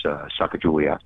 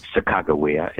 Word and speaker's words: Sacagawea: Eventually, that included, of course, Sacagawea, or Sacagawea, as we pronounced out in North Dakota.